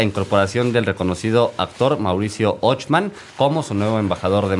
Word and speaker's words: Ochman: Es Ochman, porque tiene una H.